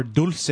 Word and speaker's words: Dulce: Uh, well, the people that are listening probably know what we're talking about when we talk about the dulce base, or dulce, if you uh, want to pronounce it properly.